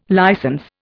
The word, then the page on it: licence: Indian